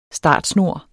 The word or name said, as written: startsnor